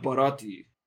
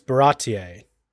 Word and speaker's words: Baratie: I think they still said Barati, but they said it very, like Barati, not Barati. – I watched the Funimation dub. They got real French with it. – Oh. – Is it like Baratier? – Yeah, it's Baratier.